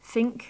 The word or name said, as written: think